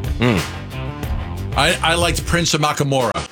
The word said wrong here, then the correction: Amukamara: I can pronounce his name. – Amukamara. – Amukamara. Was that his name? Did I get it right?